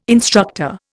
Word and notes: instructor: Indian

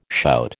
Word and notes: shout: American English

